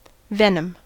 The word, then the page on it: venom: American English